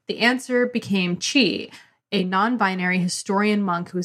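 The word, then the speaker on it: Chih: Vo says, I'm not sure if I'm pronouncing the name of the character right. It's C-H-I-H. Chi. – In the audiobook, they say Chi.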